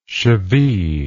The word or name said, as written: cheville